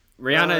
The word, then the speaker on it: Rihanna: And then Rihanna and Super Smash Brothers upcoming.